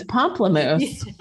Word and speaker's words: pamplemousse: Pamplemousse. Yeah. I know that's not how you say it. – No, that is actually.